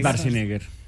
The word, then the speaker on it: Schwarzenegger: Es Schwarzenegger.